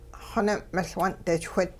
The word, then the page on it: xoning'-miL-wun'dichwit: Hupa